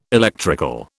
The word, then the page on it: electrical: British English